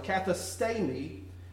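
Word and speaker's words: kathistemi: This is katha, and it's actually stay, that has a long A sound. Katha stay me, and it means to place down, to designate, or to constitute.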